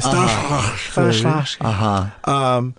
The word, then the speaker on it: Stanislavski: That's how real actors pronounce it. Stanislavski.